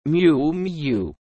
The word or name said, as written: Miu Miu